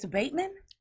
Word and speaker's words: abatement: How did I say albeit? It's abatement.